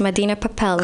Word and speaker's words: Medina Papel: So Medina Papel, am I saying it correctly? – Yes, Medina Papel.